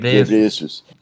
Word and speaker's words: Ghebreyesus: Dünya Sağlık Örgütü Genel Direktörü Doktor Tedros Adhanom Ghebreyesus, değil mi? Öyle mi okunuyor? – Evet, Ghebreyesus. Evet.